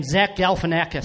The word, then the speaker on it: Zach Galifianakis: Zach Galifianakis.